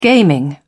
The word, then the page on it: gaming: British English